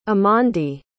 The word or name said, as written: amondi